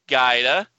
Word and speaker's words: Guida: This is Stephanie Guida.